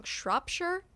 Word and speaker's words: Shropshire: I'm saying that wrong. Shropshire? It's Shropshire.